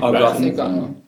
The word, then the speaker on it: au gratin: Au gratin. – Au gratin. – Oh, yeah. Well, if you... I mean, you say au gratin is the correct French pronunciation, but in English you would say...